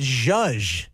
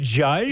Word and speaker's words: zhuzh: Z-H-U-Z-H. Zoos. – Oh, goodness. – You'll zoos up something. What does that mean? – Oh, you're mispronouncing it. Judge. – Is it judge? – Yeah, you judge it up. Yeah, you fancy something up if you're... – Judge? – Yeah, judge. Okay.